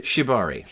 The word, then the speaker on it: Shibari: JJ and Hannah um, practicing Shivari. Am I saying it? Did I say it right? Shibari. Shivari. Yeah.